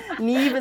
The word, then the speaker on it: Niamh: Neve is like N I A M H. There's no V.